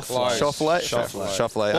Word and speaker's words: Schauffele: Xander Schauffele. Is that right? – Shoffley. – Or Xander Cage. – Yeah, Xander Cage is easier, but it's like shuffling a deck.